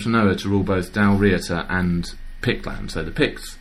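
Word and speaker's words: Dál Riata: Came pretty much from nowhere to rule over both Dalriata, or somebody pointed out to me actually on the WordPress blog, it should perhaps be pronounced Dalriata. – Why? – Because you emphasise the first syllable, apparently, in Scottish. So if you have Dal and then Riata, it would be Dalriata.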